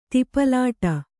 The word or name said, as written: ತಿಪಲಾಟ